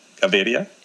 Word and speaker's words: Gaviria: Gaviria. – Gaviria. – And Contreras. – Contreras. – That's pretty good.